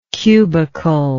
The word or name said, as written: cubicle